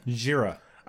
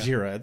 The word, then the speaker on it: Jira: Jira. – I wrote it down, but even looking at it, I'm like, I don't know how to pronounce it. It looks like Jahira, but it's Jira.